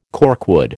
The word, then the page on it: corkwood: American English